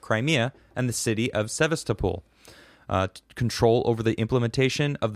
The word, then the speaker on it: Sevastopol: Sevastopol. I don't know if I say that correctly. – That's right. Sevastopol.